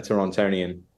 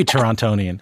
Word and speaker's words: Torontonian: a Torontonian. – Well, I felt like I pronounced the second T too hard there.